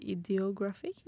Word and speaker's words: Ideographic: Um, han ga ut albumet sitt nå i mars, i Diagraphic. Ideograph, ide, ideographic? – Ideographic.